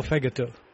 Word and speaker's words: fegato: Now, I do love the, is it pronounced fagato or fegato? – Fegato.